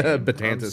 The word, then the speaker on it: Betances: Betances.